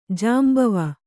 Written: ಜಾಂಬವ

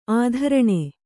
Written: ಆಧೋರಣ

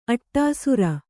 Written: ಅಡೀಸೇರು

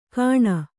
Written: ಕಾಣ

